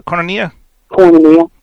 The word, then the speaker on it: Koinonia: Koinonia.